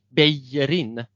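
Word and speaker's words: Bellerín: Ska man uttala det på liksom mm. katalianska eller spanska så blir det Beijerin.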